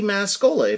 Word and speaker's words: Mascola: which is Andy Mascola, if you're a new listener. And my last name is spelled M-A-S, as in Sam, C-O-L-A, as in Coca-Cola.